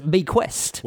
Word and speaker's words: bequest: Or no, it's have a look at how it's spelled here, like how they say to sound it out. B-I-K-W-E-S-T. Bequest.